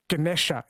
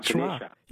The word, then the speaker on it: Ganesha: Or they call the elephant god Ganesha in the south, Ganesh in the north. They delete the A's. – Yeah, so the A at the end is pronounced like a schwa, Ganesha.